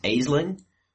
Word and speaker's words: Aisling: because I'm Scottish myself, that it would be pronounced Aisling, because that would be the original Celtic pronunciation, but whatever.